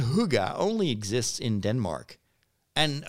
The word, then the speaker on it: hygge: You spell it H Y G G E, but according to Wikipedia, you have to pronounce it as uh, Hygge. Uh, almost sounds like a from a, from a song in uh, Guardians of the Galaxy. Hygge, Hygge.